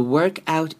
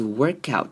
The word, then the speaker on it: workout: 好 The workout enthusiast. 这边要特别注意的是 workout 这个字。这个字呢首先是 OR 这边这个声音要念成 R. Workout. Workout. 不会是 workout, 会是 workout, 这样子的念法。